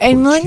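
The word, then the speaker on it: paczki: O-O-N-C-H-I-E, like punschki. – That ain't punschki. And no, it's P-A-C-Z-K-I, and they're like, what? What? No, it's punschki.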